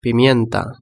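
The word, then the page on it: pimienta: Spanish (Spain)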